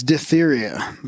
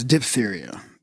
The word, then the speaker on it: diphtheria: So you said several times diphtheria, but the proper pronunciation is diphtheria. The extra H is probably what threw you off.